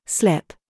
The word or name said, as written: KIT